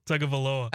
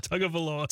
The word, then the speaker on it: Tagovailoa: Tagovailoa. – I don't think Chad said it right on the Tagovailoa.